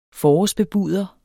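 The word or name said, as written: FORÅRSBEBUDER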